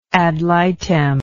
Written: ad litem

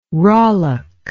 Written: rollock

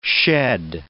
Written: shed

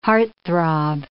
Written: heartthrob

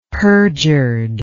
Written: perjured

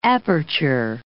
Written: aperture

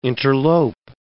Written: interlope

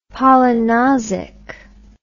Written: polynosic

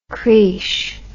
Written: creesh

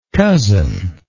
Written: cousinship

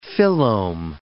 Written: phyllome

